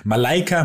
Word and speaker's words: Malaika: Malika äh, Malika Mi Malaika Mihambo, mein Gott.